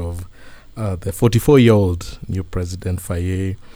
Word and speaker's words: Faye: Is it Faye? Faye? Yes, Faye, correct.